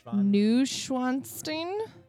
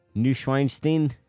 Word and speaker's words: Neuschwanstein: Neuschwanstein. – Neuschwanstein. – Yeah, Google this word that you will never be able to spell. – Neuschwanstein.